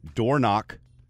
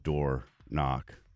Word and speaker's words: Dornoch: We start with Dornock. It's spelled D-O-R-N-O-C-H, not door knock.